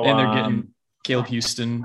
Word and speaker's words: Caleb Houston: Caleb Houston, I said that right.